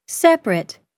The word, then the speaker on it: separate: The vowel immediately after a stressed syllable is sometimes not pronounced. – Average. – Different. – Separate.